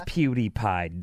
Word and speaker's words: PewDiePie: In fact, I think I said his name wrong, and one of our interns was like, oh, you old guy. It's PewDiePie, dummy.